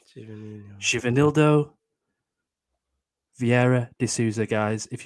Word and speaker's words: Givanildo: It's the first word is spelled Givanildo. Givenildo.